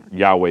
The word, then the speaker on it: Yahweh: Yahweh is the proper pronunciation of that.